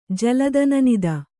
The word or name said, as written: ಜಲದನನಿದ